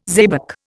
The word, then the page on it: xebec: Indian